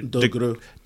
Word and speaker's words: DeGruy: Dr. Joy DeGruy. Is that how you know her? Is that how you say her name? Dr. DeGruy.